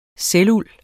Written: celluld

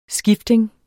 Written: skifting

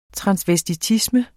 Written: transvestisme

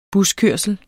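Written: buskørsel

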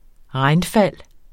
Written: regnfald

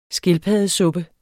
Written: skildpaddesuppe

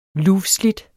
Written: luvslidt

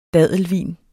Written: daddelvin

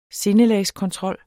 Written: sindelagskontrol

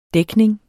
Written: dækning

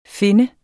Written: findes

